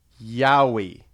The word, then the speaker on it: yaoi: You wanted to help, just wanted to help you out and let you know it's pronounced yaoi. – Okay. – You know, like the Australian version of Bigfoot.